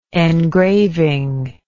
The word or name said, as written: engraving